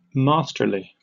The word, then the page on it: masterly: British English